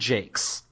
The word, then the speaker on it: Jacques: Also, according to Wikipedia, it's pronounced Brian Jakes. – Brian Jakes. Okay. Well, I was Brian joking when I said that. So. I always pronounce it Brian Jacques. Brian Jacques, by the way, of Redwall fame, of course.